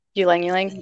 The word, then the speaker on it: Ylang Ylang: I know that Ling Ling, did I say that right? Ling Ling? – Ylang Ylang, yeah.